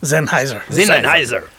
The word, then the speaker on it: Sennheiser: And people don't know this, but is a rep from Sennheiser. – Oh! – Did I say that right? – Yes, sir. – Is it Sennheiser? – Sennheiser.